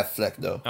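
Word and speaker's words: Flecknoe: I believe that's how you pronounce her name. – Yeah, Flecno.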